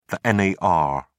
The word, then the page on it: nar: British English